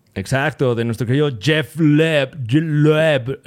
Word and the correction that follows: Loeb: nunca he sabido cómo se pronuncia. Jeff. Jeff Loeb. – Jeff Leb. – Leb. – Yo le digo. Loeb. Loeb. Loeb.